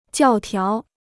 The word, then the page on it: 教条: jiào tiáo